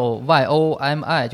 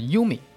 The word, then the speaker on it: Yomi: 这游戏英文名字叫它应该怎么读呢？ – 应该叫 YOMI，就是 Yumi。其实它是一个日语的一个词，就是阅读的意思。